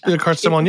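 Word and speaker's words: Billecart-Salmon: So I do have a bottle, a brand that I want to mention, and I butcher pronunciations constantly, but Bilkar Samon, is that how you say it? – Bilkar Samon, yeah, that's yeah. Good enough. Everyone knows what that is.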